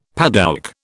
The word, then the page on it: padouk: British English